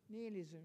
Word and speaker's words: nihilism: Am, I saying, it, am I saying it right? – Ni- nihilism. – Nihilism, yeah yeah, yeah.